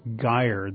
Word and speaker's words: gyre: There's this big Pacific gyre that we know about. – Yes. – I think that's the way you pronounce it. – Gyre. – Gyre. Okay.